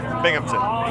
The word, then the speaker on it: Binghamton: Bimington. How do you say it? – Binghamton. – Binghamton. – Yeah. – Oh, I spelled it wrong. How do you spell it? – B-I-N-G. – Oh. – H-A-M.